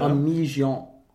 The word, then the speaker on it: Amaziane: So, this is supposed to be pronounced in a French accent. – Okay. – Amesian. – Okay. – A-M-A-Z-I-A-N-E. – A-M-A-A-M-E. – No, A-M-A. Like amazing. So yeah. A-M-A-Z. I-A-N-E.